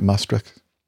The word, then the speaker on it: Maastricht: was in the initial climb out of Maastricht. Am I saying that right? Maastricht? A runway 21? – Yep, Maastricht.